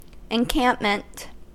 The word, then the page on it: encampment: American English